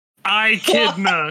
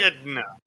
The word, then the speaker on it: echidna: The E is pronounced like the I in the name Ricky. I've never heard someone say it like that. It's like the middle pronoun is pronounced like kid. The last part is pronounced, if it were spelled na, rhymes with huh. I kid na.